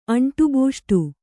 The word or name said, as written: ಅಂಟುಬೂಷ್ಟು